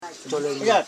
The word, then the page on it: cholening: cho.le.ning